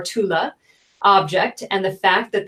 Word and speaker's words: Thule: Is it Tula or Thule? The Ultima Thule? – Oh, Thule Society? – Yeah. – T-H-U-L-E. – Oh, yeah, exactly.